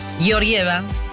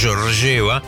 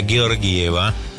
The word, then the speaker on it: Georgieva: Georgieva. – Mira cómo sabe, ¿eh? Sí, yo lo estuve buscando, porque todos le decimos Georgieva. En realidad hay que pronunciarlo con la G cerrada, Georgieva.